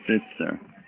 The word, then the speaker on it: Spitzer: It's Spitzer.